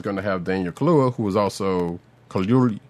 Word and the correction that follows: Kaluuya: Kaluuya, excuse me, I'm butchering his name, but who also played her husband in the the the MCU uh, in in Black Panther.